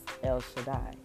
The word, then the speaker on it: El Shaddai: But my entire middle name is El Shaddai, and it's pronounced El Shaddai, E-L Shaddai, like that, right?